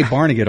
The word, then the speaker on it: Barnegat: We say Barnegat on the East Coast.